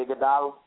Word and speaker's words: Iguodala: Iguodala, what his name is? – Andre Iguodala.